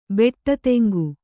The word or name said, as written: ಬೆಟ್ಟತೆಂಗು